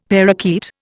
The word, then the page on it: parrakeet: Indian